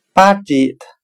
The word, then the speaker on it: budget: 我们可以用谐音法来记忆这个单词 budget，它的发音很像汉语的巴结他，巴结他指的就是要讨好他。